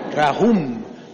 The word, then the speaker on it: rahum: Rahum, con H, rahum, M al final, rahum.